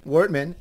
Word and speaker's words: Wertmann: Wertmann.